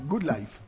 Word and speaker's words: Good Life: One Republic, Go Life, Good Life. Que luego me dice eh, Zara que pronuncio mal. Good Life.